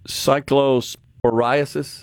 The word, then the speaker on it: Cyclosporiasis: Cyclosporiasis? – That's correct.